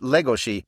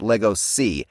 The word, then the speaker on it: Legoshi: Oh, yes, it's pronounced Legoshi. It is based on Bela Lugosi, who's everybody calls Bela Lugosi, which is not the actual way to pronounce his name. So basically, his character's name is actually pronounced Legoshi, not Legosi.